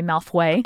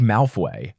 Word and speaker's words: Malfoy: You said that in your brain you pronounced Malfoy Malfway. – Oh yeah. I thought it was like Malfoy.